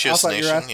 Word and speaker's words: ratiocination: How do you pronounce that? Ratianization? Whatever it is. – Something oh, okay, like okay. – Ratiocination, yeah.